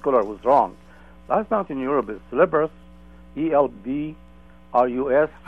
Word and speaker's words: Elbrus: E-L-B, as in boy, yeah. – R-U-S. – Elbrus.